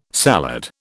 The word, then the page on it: salad: British English